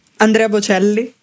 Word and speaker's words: Andrea Bocelli: How do you pronounce that properly? – Andrea Bocelli. – That's the one.